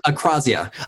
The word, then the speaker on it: akrasia: I use the term akrasia. I think I'm pronouncing that correctly. – Yeah, akrasia. – Akrasia. – It's pronounced both ways. I think akrasia is the technical one.